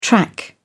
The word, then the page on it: track: British English